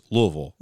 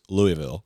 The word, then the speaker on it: Louisville: If you're from Louisville, it's Louisville. – Louisville. – If you're from southeastern Illinois, it's Louisville.